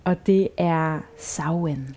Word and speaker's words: Samhain: Den staves jeg nemlig Samhain, men udtalt Samhain.